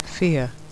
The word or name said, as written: fear